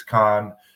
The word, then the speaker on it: Cannes: Cannes is perfect. Everybody calls it Cannes. It's Cannes, the city in the south where the film festival is. That is Cannes.